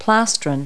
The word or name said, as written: plastron